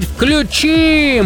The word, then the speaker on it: включи: Согласно строгой литературной, хочу подчеркнуть, норме, ударение в различных формах данного глагола сохраняется на том же слоге, что и в начальной. Поэтому правильно говорить «включи».